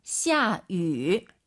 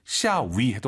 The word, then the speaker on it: 下雨: 下雨. – 입술을 동그랗게 말아서 위 하려고 하세요. 下雨. 샤우. 네, 요렇게 발음하셔야 됩니다. 절대 위, 입이 가로로 찢어지면 안 됩니다, 여러분. 물론 샤위 해도 알아듣지만 말이죠.